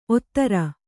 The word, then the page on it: ಓತಾರಿ: kannada